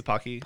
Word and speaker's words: Pocky: The pokies, pockies. – The pocky. – Pockies. – Pocky. Yeah.